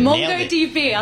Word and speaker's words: MongoDB: We see Meta tapped, I don't know how to say the company name, MongoDB, very good. – MongoDB.